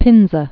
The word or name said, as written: Ezio Pinza